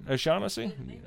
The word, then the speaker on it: O'Shaughnessy: Around that time, Sir William Brooke O'Shaughnessy did I say that right? O'Shaughnessy? I sure did it.